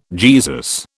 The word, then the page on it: Jesus: British English